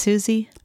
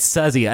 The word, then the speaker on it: Suzzy: So it's three sisters, Maggie, Terry, and Suzzy Roach. And they formed this trio in 1973 and made albums. – Sure, that sounds Susie. – It's Suzzy. I trust on this. It's S-U-Z-Z-Y, Suzzy.